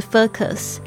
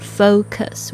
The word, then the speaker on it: focus: 如果你一直专注在这个，注意一下 focus 这个 focus 的发音哦，不是 focus，那个 o 要发的很完整 focus。